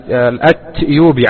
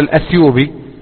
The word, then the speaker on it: الأتيوبي: ومن طرائف ما يروى أن شيخنا الشيخ العلامة محمد بن علي آدم الأتيوبي، عفوا كنت سأقول الأثيوبي، الأتيوبي، يكتب في كتاباته وفي كتبه بالتاء، بينما السائد في النطق أن الناس يقولون الأثيوبي له ولغيره.